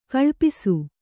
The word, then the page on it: ಕಳ್ಪಿಸು: kaḷpisu